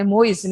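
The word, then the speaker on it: Moïse: é Moise, né, a pronúncia do nome dele,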